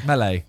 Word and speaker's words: Melee: Melee.